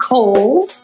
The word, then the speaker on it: Cowles: It is pronounced Cowles. George Cowles was an early land owner developer out in the East County area. So Cowles Mountain, like the department store, we want to respect the dead, right? It's a namesake, so we want to pronounce it correctly.